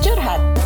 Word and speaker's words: curhat: Nope. It's pronounced Curhat. It's an Indonesian term.